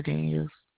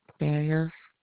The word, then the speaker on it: Daniels: Sharonda Daniels. – What is it, ma'am? – Sharonda Daniels. – Okay, how do you spell that? – S-H-A-R-O-N-D-A. Daniels, D-A-N-I-E-L-S.